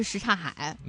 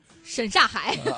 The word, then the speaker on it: 什刹海: 所以我们真的要给大家普及，一个是这个大石烂，一个是什刹海。 – 什刹海会读成什么呢？ – 沈刹海。